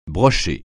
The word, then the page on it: broché: francais